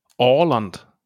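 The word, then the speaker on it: Åland: Ja ich glaube, das spricht man es Ärland? Nee, Arland, oder? Warte, ich drücke mal drauf. – Orland. Orland. Orland heißt es.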